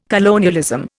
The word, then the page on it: colonialism: Indian